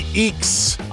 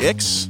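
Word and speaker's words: Ickx: It's Past Gas, and this week we're talking Jackie Eakes. I spent half the episode pronouncing it Jackie Ix and was corrected about halfway through, so just deal with that.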